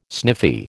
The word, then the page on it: sniffy: American English